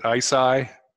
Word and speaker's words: ICEYE: Is it ICEYE? – That's exactly right.